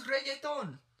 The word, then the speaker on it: reggaeton: So it's reggaeton.